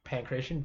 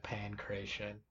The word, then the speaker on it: pankration: How do you spell that? – Pancration, PanKraTion. Okay. Yeah, Pancration.